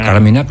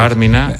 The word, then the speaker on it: Carmina: Karl Orff, Carmina Burana, acentuado en la primera A. – En la Carmina, claro. – Carmina.